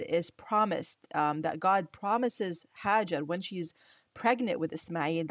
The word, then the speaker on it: Hajar: I'll say her name in Arabic and in English, you know, Hagar or Hajar, as we say in Arabic,